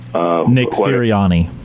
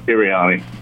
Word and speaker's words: Sirianni: You know, I will say this about the Philadelphia head coach, and I can't pronounce his name. – Nick Sirianni. – Sirianni, thank you.